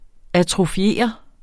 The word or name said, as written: atrofiere